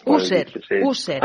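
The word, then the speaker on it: Usher: Y además he leído, doctor, que esta retinitis es parte de una afección más amplia llamada síndrome de no sé cómo se dice, esto. – User. Ah, el síndrome de User.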